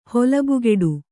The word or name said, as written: ಹೊಲಬುಗೆಡು